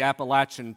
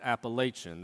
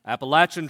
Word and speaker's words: Appalachian: I've always been fascinated a bit with the Appalachian Trail, and that is how you say it. Not Appalachian, that's a bad word in my family. Appalachian Trail.